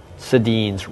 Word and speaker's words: Sedin: Yes, they have actually been the faces for a while of Jeans Day, and not just because Sedin's rhymes with jeans perfectly.